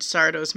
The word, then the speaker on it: Sardo: As I've mentioned, they are in Sardo's Magic Mansion. That is Sardo, no accent on the do, and no mister.